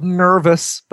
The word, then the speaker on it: Nurvis: The band you're hearing right now is called Nervous, but it's spelled N-U-R-V-I-S.